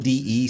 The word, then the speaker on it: De: Yeah, we should It's D Williams. That's his thing? It's actually it's spelled D E, so it's de, but everybody says D, so I guess we just say D.